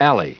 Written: alley

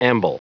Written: amble

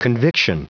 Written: conviction

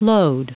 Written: lode

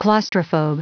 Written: claustrophobe

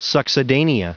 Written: succedanea

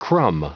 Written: crumb

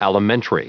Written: alimentary

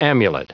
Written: amulet

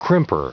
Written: crimper

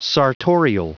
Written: sartorial

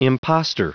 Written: imposter

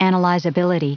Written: analyzability

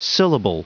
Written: syllable

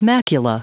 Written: macula

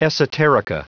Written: esoterica

